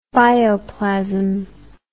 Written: bioplasm

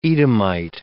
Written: Edomite

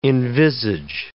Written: envisage

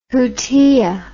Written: hutia

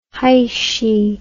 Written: heishi

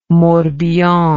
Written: Morbihan

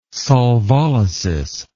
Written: solvolysis

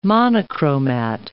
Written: monochromat

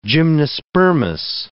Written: gymnospermous

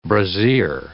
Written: brassiere

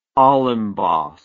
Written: olimbos

